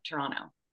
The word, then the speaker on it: Toronto: The T is silent, Toronto.